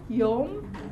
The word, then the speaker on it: yom: Okay, that's the O sound there, the O syllable, O, O, yom.